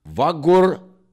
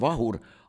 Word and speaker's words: Vahur: Vagur G-ga, muide. Vagur Laiapea. Vagur. Sellepärast, et nad teavad ju täpselt, et on Vahur, aga...